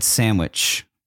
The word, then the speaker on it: sandwich: The D is silent, is it not? – I say sandwich. Yeah, I don't use the D, but I don't say Sam with an M.